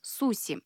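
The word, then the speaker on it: суси (寿司): Однако одно можно сказать точно – правильным произношением рисовых шариков с морепродуктами японцы считают суси.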